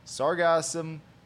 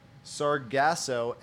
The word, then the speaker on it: sargassum: Well, you know, there's actually two ways to pronounce it. Sargasso and sargassum. – Right. I always do sargasso, and I drop the M.